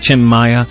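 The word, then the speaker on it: Maia: If he was from the States, he'd be Tim Maya. But Brazilian pronunciation of his name, Tim Maya.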